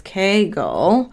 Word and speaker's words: Kegel: So I think the actual pronunciation is Kegel.